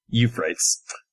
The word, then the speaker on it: Euphrates: Okay, so I am playing Tommy Euphrates, spelled Euphrates, pronounced Euphrates. Tommy is a young street tough.